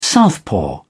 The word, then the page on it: southpaw: British English